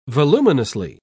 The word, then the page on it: voluminously: British English